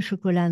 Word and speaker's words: chocolat: So now the lengthening of the last syllable of chocolat is no longer there. It's j'aime le chocolat noir.